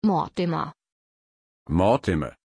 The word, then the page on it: Mortimer: German